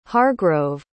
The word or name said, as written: hargrove